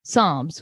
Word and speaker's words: Psalms: Tonight, we're covering episodes 1 and 2, titled Book 1, Genesis, and Book 2, Psalms, which honestly, had to make sure that's how I was pronouncing it correctly. That P threw me off.